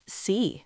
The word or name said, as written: sea